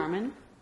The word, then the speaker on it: Carmen: I hate Carmine. That's her name. Carmen? – Carmen. – Is her name pronounced Carmen? – Yeah. Oh, that's weird that it's spelled that way.